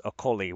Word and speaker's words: Okolie: I'm learning the correct pronunciation. It's Okolie, which is just devastating as someone who is enjoying making Oakley-Dokley jokes.